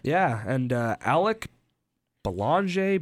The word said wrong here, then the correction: Belanger: Belanger. – I should know that.